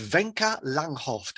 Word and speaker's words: Wenke Langhof: Their name is Wenke Langhof.